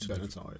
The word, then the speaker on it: Benatar: Pat Benatar. – Is that how you pronounce it? – Benatar, yeah.